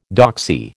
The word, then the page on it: doxy: American English